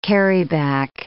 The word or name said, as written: carryback